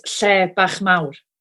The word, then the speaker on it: Lle Bach Mawr: She is co-presenter on the popular interiorism programme, Dandel, and Wow. Mandy, did I say that right? – Almost. Lle bach mawr. – That's it.